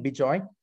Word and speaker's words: Bejoy: So it's my first name, Bejoy, spelled as it is pronounced, and @bob.ai.